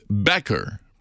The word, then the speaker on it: Becker: So his name was B-A-C-K-E-R. Another one used the name Becker, because that's how it's pronounced, B-E-C-K-E-R.